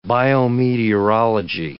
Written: biometeorology

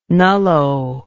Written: nullo

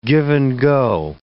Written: give-and-go

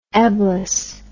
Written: eblis

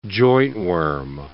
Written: jointworm

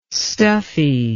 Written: stuffy

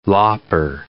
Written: lopper

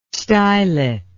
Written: stylet

